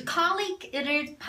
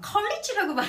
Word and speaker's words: colleague: 그 colleague를 발음을 가끔 잘못하시는 분들도 있어요. 뭐 예를 들어서 뭐 막 colleagues 이렇게 한다거나 아니면 막 college라고 발음하시는 분들도 들어본 적 있었던 맞아요. 것 같아요. – 왜냐면 college라고 하면은 soft G고 네. colleague라고 하면은 hard G.